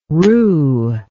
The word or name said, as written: roo